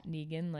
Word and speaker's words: Negan: His name is Negan in the show. – Negan, like... spelled like Megan, but...